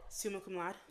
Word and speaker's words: summa cum laude: And you should be celebrating, because again, how do you say it? – Summa cum laude.